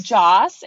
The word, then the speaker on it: Joss: It's Joss. It's short for, I believe it's Joseph, but he was called Joss.